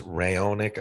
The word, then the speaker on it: Raonic: Milos, what's his name? Ronich? Yes, sorry, Milos Raonic.